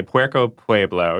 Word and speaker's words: Puerco Pueblo: And you said Puerco Pueblo. Puerco Pueblo. – Yes. It's tricky to say. Puerco Pueblo. You kind of have to say it in slow motion to get it right.